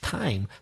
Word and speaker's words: thyme: I'm talking about T-H-Y-M-E, time. The herb, which is tomillo. – Tomillo. – T-H-Y-M-E is pronounced time. So there are a few T-H words which are pronounced as if it was just the letter T. – And you said the third type. What kind of sound is T-H-I-R-D? – And third.